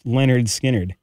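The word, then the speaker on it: Lynyrd Skynyrd: Off of their 1973 album, Leonard, or pronounced Leonard Skinnerd, teaching you how to pronounce their high school gym teacher's name.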